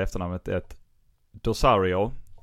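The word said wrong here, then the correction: D'Orazio: eller Dorazio, förlåt mig, Valerie Dorazio.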